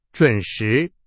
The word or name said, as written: 准时